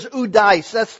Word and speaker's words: oudeis: In other words, the word here is udeis. That's the word. O-U-D-E-I-S in the original language.